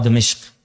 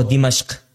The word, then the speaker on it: Dimashq: I think also with what Shaykh is saying, it's very important, and the manhaj of, as you said, the ulama of Dimashq, right? Or Dimashq, what's the correct pronunciation? – Lughatan, Dimashq wa Dimashq.